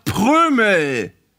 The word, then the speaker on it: Prömel: Der heißt aber doch Prömel. Mensch. Der wird mit Ö M geschrieben. Es gibt kein Wort, das mit einem Vokal und einem M hinterher geschrieben wird und wo das M kurz ausgesprochen wird, außer vielleicht Oma.